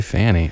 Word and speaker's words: Fani: Yeah, there is an interesting definition that this gal, District Attorney Fanny Willis, F-A-N-I, says Fanny to me, but I'm sure it's spelled, said Fanny. – It does say Fanny.